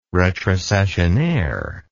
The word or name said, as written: retrocessionaire